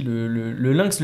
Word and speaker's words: Lynx: Ok, donc en fait le crédit le Lynx, excuse-moi, comment tu le prononces? – Le Lynx. – D'accord, voilà, ça s'appelle DigiLynx. Donc le Lynx, c'est l'unité Lynx comme un lien.